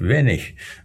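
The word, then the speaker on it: wenig: Кротов плохо, кстати, говорит, там по-немецки говорите «веник». Какой веник? Веник? Он так произносится, веник.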